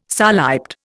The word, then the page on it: soliped: Indian